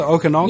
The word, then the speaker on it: Okanagan: Is that how you pronounce it, Okanagan? – Yes.